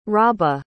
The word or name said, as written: rabah